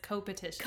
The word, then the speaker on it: coopetition: Or is it co-opetition? Because there's two O's. – Co-opetition. – I think it's co-opetition. – Co-op. Yeah. – Or co-petition. – Co-petition. – Either one is fine. It's spelled, in case you guys are literary geniuses and you know how, can you tell us how to pronounce this? COOPETITION. – Yeah. – I would say it's co-opetition.